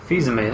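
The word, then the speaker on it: Fils-Aimé: Fizume, that's it.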